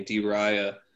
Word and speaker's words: Die Reihe: Yeah, but he records music under this German name, D. Raya. And how do you spell that? – D-I-E-R-E-I-H-E.